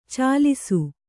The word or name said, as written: ಚೆಲ್ಲಿಸು